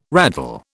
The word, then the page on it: raddle: British English